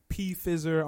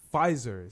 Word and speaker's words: Pfizer: Pfizer or P-Pfizer. I don't know how to Pfizer. – Pfizer is how you pronounce it.